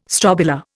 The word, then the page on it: strobila: Indian